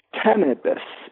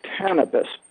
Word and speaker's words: Tanibis: Tanibus. It's not like cannabis, it's Tanibus. T-A-N-I-B-I-S.